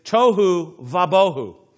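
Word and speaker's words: tohu vabohu (תֹהוּ וָבֹהוּ): It's tohu vabohu.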